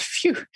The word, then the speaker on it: fiu: Like I write very briefly in the book about this Tahitian idea of phew, F-I-U, and I love that it's pronounced phew as well,